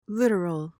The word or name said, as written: littoral